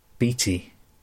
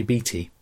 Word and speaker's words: Beatty: He doesn't always say Beatty, which is another pronunciation of that name. He sometimes seems to put an extra syllable in there. It's kind of Beatty. It almost sounds like deity. So deity, Beatty. Maybe this is a godlike character. Sometimes it sounds like the beginning of beatify.